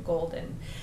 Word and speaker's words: Gollden: which is under my artist name Golden, and it's Golden spelled with two L's.